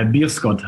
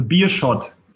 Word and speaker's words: Beerschot: Nee, hast du nicht, aber es macht nichts. – Nein, habe ich nicht. Wie spricht man das richtig aus? – Ja, Bierscott heißt der Verein. – Bierscott, okay. – Also Bierschot, für die HSV-Fans einfach zu merken, Bierschot, Schott-Bier.